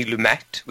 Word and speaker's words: Lumet: Failsafe is a 1964 Cold War thriller directed by Sidney Lumet, which is apparently the correct pronunciation of his name, based on the 1962 novel of the same name by Eugene Burdick and Harvey Wheeler.